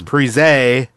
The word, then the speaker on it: Parise: Parisi got props. – Michelle Granlund? – Oh, and they also couldn't pronounce Parisi's name right because it was Parise.